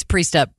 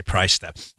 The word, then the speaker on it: Priestap: You know, I think it's Priestap. Priestap. Priestap. I always say Price Step.